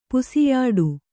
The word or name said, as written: ಪಸೆಯಿಡು